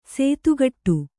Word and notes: ಸೇತುಗಟ್ಟು: sētugaṭṭu

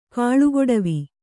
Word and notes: ಕಾಲುಗೊಡವೆ: kannada; kālugoḍave

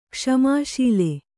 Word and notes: ಕ್ಷಮಾಶೀಲ: kṣamā śila

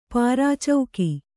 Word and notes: ಪೆರ್ಚುಗೆ: Kannada

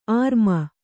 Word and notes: ಆರ್ಮ: ārma

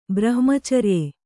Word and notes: ಬ್ರಹ್ಮಚರ್ಯೆ: brahmacarye

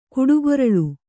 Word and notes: ಕುಡುಗೊರಳು: kuḍugoraḷu

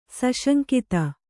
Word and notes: ಸುಸಂಗತ: Kannada

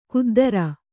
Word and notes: ಖುದ್ದರ: khuddara

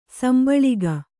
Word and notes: ಸಂಭಾಳಿಕೆ: sambhāḷike

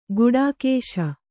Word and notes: ಘಟಾಕಾಶ: Kannada

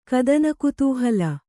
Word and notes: ಕದನಕುತೂಹಲ: Kannada; kadanakutūhala

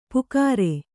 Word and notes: ಪುಕಾರೆ: Kannada

